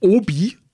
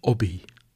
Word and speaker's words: Obi: Also eigentlich heißt es nicht Obi, deutsch, wie bei uns ausgesprochen, sondern Obi. – Obi. – Wie Hobby ohne H halt. Ein bisschen näselnd, wie so die Franzosen halt.